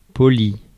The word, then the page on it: poli: [pɔ.li]; France (Paris)